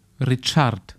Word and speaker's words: Richard: Mich übrigens mal erkundigt, kurzer Einwurf, wie Richard auf Spanisch ausgesprochen wird. Und das ist dann Richard.